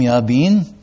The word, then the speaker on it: Jabin: "Yahweh sold them into the hand of Jabin." If you were Hebrew, you'd pronounce his name, Yabin.